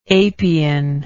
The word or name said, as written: apian